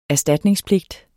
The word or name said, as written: erstatningspligt